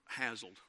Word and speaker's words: hassled: You know, she's hazled. The kids are hazled, Um, hassled. Razzled, thank you. Um, uh, put the wrong, the emphasis on the wrong syllable there, didn't I?